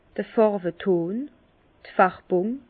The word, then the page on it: d' Fàrweton, d' Färbùng: Bas-Rhin; Herrlisheim